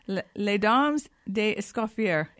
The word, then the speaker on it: Les Dames d'Escoffier: The name has actually changed because it is an annual fundraising event for Les Dames d'Escoffier International. Did I say that right? – You did.